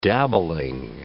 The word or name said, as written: dabbling